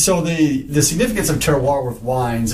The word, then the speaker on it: terroir: They refer to something, and how do you pronounce it? – Terroir. – Terrier.